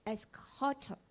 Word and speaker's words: eschatos: 它其实是 eschatos，那个 x 呢它是 c 跟 k 的发音啊。